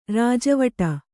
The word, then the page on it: ರಾಜವಾಡೆ: kannada